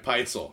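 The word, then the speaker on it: Pitezel: How did we decide to pronounce that? – I think we decided Peitzel.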